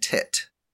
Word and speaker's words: teat: He once started to write an essay about the word T-E-A-T, which Webster II pronounced teat. Gove, who worked on his own farm on the weekends, had never heard it pronounced any other way than tit, except by silly people who had never in their lives milked a cow.